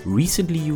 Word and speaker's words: Recently: Welche Ersetzungsstrategien es gibt, wenn der Cache voll ist, sowas wie LIFO, FIFO, Least Frequently Used und Least Recurrently. Da merkt man, wie schwierig Cache-Probleme eigentlich sind, sogar mit der Aussprache. Du darfst nochmal probieren. – Least Frequently Used und Least Recently Used was ein exklusiver und inklusiver Cache ist und final, warum Cache-Invalidierung eigentlich so ein hartes Problem in der Informatik ist.